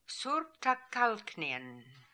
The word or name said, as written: Soorptakalknian